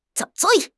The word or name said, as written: zui3